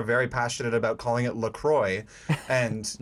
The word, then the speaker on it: La Croix: It's pronounced La Croix.